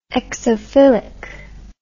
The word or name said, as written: exophily